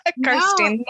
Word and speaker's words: Kirsteen: So yeah, I thought maybe Tom would have told you it's Kirstein. – No, I was just guessing. – Well, yeah, good guess. You are pronouncing it right.